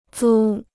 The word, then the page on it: 租: zū